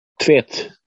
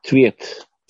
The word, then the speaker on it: Tvedt: Tvet. T-V-E-D-T. Tvet.